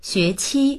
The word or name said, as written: xue2qi1